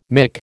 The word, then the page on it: mick: American English